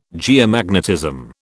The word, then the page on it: geomagnetism: British English